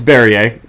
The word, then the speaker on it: Barriere: So Eric Barrier, Barriere. I'm going to pronounce everyone's name wrong. I'll just a fair warning. Hit me on Twitter if you want. Eastern Washington's QB. – Okay. – It's Barriere, but for my quarterback, I'm going to take Case Cookus from Northern Arizona.